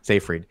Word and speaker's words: Seyfried: Seyfried.